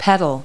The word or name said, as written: pedal